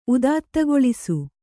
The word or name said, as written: ಉದಾತ್ತಗೊಳಿಸು